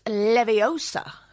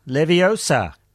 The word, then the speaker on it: Leviosa: Wingardium Leviosa. – Nah, man, you're saying it wrong. It's Leviosa. – Leviosa, cunt.